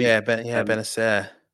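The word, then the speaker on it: Bennacer: And is it Benacer? Is that how you pronounce it right? Obviously, Benacer. Yeah.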